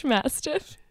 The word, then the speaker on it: mastiff: He's the English master. Did I say that right? What is he? – An English mastiff.